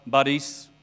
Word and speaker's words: Boris: Boris Yeltsin? No. Boris. We get the emphasis on the wrong syllable. You've got to get it on the right syllable.